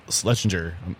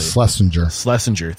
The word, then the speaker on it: Schlesinger: Hart, H-A-R-T. – So you opened Tremont with Chris Hart. – Yes. – And Chris Schlesinger, I'm not saying that correctly. – Schlesinger, thank you very much.